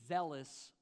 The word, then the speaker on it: zealous: zealous for the Lord. This word zealous, it rhymes with jealous, but what it really is a sense of excitement and fervor and passion for the things of God.